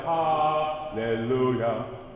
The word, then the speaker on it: hallelujah: What's the vowel on hallelujah? We tend to kind of soften that to hallelujah. We've always got to aim for the oo sound. Hallelujah. And lu is always the operative syllable, right? The ha is softer, the le is softer, lu is loudest.